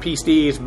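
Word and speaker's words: pistis (πίστις): Literally, I know the term should be pronounced pistis, but it's anglicized somewhat, and just the way I do it, unfortunately. But that is technically the correct pronunciation.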